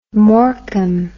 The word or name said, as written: morecambe